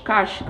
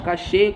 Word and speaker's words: cache: Vamos falar agora sobre a memória cache, cache, cache como vocês quiserem pronunciar.